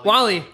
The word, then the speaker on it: WALL-E: WALL-E.